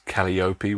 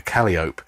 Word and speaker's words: calliope: I think Rollin later on pronounced it as calliope, which, from what I can tell, is the wrong pronunciation. A calliope, which is a sort of steam-powered organ.